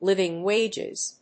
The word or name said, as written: living wages